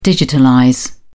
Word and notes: digitalize: British English

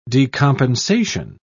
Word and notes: decompensation: British English

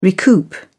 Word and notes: recoup: British English

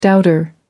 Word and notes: doubter: American English

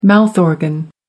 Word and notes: mouth organ: American English

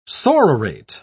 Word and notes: sororate: British English

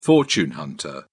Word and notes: fortune hunter: British English

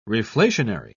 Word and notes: reflationary: British English

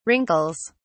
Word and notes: wrinkles: British English

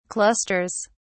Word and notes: clusters: British English

